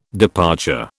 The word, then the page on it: departure: British English